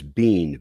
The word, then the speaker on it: Bean: The first name is Jeffrey, J-E-O-F-R-E-Y. Last name is Bean, B-E-A-N, Jeffrey Bean.